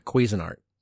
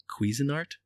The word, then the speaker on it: Cuisinart: Cuisine Art. How do you say it? – A Cuisinart. – Cuisinart? – Yeah.